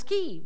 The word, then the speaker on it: Kyiv: He talked about borscht and how to pronounce the city of Kyiv correctly. The Ukrainian pronunciation is Kyiv.